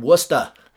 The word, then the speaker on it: Worcester: Washington, D.C., Atlanta were in front of us, but then also some other cities like Salt Lake, Portland, Dallas, Raleigh-Durham, and Worcester, Mass.